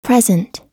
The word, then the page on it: present: American English